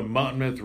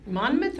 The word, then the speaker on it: Monmouth: We're already in 2020 season mode in the land of the Monmouth Rage football team. – Monmouth or Monmouth? – Them, the Rage. – Do you pronounce the N?